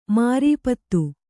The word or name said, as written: ಮಾರೀಪತ